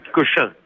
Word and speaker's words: Cochin: Ça s'écrit Cochin, comme l'hôpital Cochin.